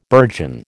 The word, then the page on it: burgeon: American English